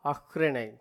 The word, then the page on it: அஃறிணை: Tamil